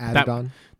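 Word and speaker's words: Adidon: Because first of all, the name of it, Adedinon, I don't know if I'm saying it right. – Adedon.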